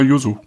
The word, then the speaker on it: Yuzu: Spricht man es überhaupt Yuzu aus? Ja, ja, Yuzu.